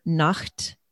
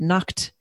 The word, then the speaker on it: Nacht: But I learned the word Nacht, which means night. But one time I pronounced it incorrectly. I said Nacht. And I was corrected quickly because that means naked.